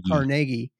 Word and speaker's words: Carnegie: I, I never say his name right. It's not Carnegie. – Carnegie. It's like Carnegie.